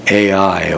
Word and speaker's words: Ai: We'll draw some lessons between the approach of taking Jericho and taking AI, or as it's pronounced really, I think from what I've heard, I. It's spelled AI, but it's I.